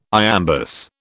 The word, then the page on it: iambus: British English